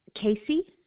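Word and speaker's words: Kacie: Casey.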